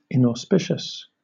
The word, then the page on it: inauspicious: British English